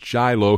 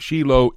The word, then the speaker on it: Shiloh: You may pronounce it Shiloh here in America, but it's Shiloh in Hebrew.